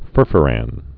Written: furfurans'

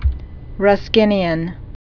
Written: Ruskinian

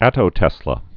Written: attotesla

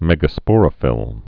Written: megasporophylls'

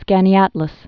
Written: Skaneateles Lake

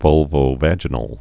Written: vulvovaginal

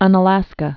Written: Unalaska Island

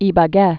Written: Ibague

